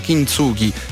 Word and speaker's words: Kintsugi: il nuovo album Kintsugi. Spesso e volentieri l'ho chiamato Kintsugi, non so, però la pronuncia corretta è Kintsugi, e sarà fuori il 27 novembre, disco molto personale ma soprattutto prodotto da Dardust, quindi qualità assicurata.